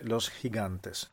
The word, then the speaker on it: Los Gigantes: Da lì, per la prima volta vidi i giganti di Tenerife, una enorme scogliera chiamata appunto Los Gigantes in spagnolo, per meglio dire Los Gigantes, questa è la pronuncia corretta,